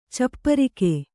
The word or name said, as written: ಚಪ್ಪರಿಕೆ